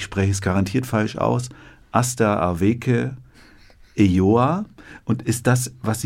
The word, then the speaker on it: Aster Aweke: Sie heißt Aster Aweke. Aster Aweke. Aweke.